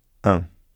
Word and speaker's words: un: Let the air go through both your mouth and your nose when you pronounce un. Don't pronounce the letter n.